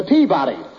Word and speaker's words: Peabody: You've got the wrong pronunciation. It's the Peabody.